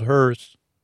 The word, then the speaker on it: Hearth: So they had a backup Earth called Hearth with a silent H, but I say it now just so you know it's called Hearth.